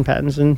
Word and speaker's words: Pattinson: Did I say his name right? – Pattinson. – I think it's Pattinson.